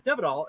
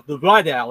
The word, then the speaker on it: de Vidal: When we were growing up, we always said Devidal, and then I've talked to several people who came from France, and they said, no, Duvidal or Duvidal.